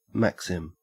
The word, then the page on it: maxim: Australian English